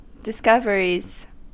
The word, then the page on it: discoveries: American English